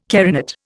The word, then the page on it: carinate: Indian